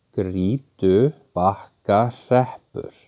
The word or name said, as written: Grýtubakkahreppur